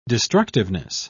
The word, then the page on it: destructiveness: British English